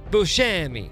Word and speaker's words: Buscemi: I went back and watched a late night interview where he does clarify that his name is pronounced Buscemi.